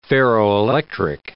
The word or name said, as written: ferroelectric